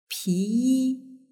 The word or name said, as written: piyi